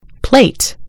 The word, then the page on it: plate: American English